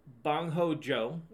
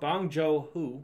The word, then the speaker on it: Bong Joon-ho: So originally, it was Bong Ho Jo. – Right. – Bong Ho, yeah. – Sounds like you're saying it wrong. – Bong Joon-ho.